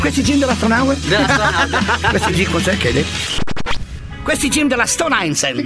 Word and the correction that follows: Stone Island: Questi gym della Stone Islands. – Stone Islands. – Ma perché scrivono? Scrivono come si legge. – Si chiama così, Stone Island.